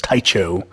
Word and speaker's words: Tycho: But I did try to represent that some, with a San Francisco band, Tycho. I believe that's how you pronounce that, is Tycho.